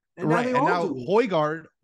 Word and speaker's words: Højgaard: Højgaard, who won a couple weeks ago in Europe, is like 192, 193. That's a pretty good pronunciation.